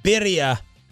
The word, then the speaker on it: birria: Birria, as Atola was saying.